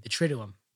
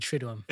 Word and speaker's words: Triduum: But some things that we do want to talk about with the Triduum, like how to pronounce it. – Yes. Triduum. – You're going with Triduum. – It's Triduum.